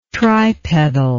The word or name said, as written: tripedal